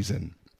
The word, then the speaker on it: zen: Et quand le euh, bouddhisme zen est introduit au Japon, les Japonais utilisent ces caractères-là avec une prononciation propre et chan en japonais se dit zen.